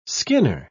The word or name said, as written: skinner